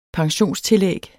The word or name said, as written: pensionstillæg